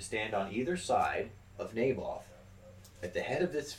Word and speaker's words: Naboth: N-A-B-O-T-H.